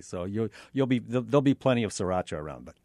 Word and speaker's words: sriracha: In some of the Asian restaurants, the popular condiment, the big red bottle, it's spelled S-R-I-R-A-C-H-A.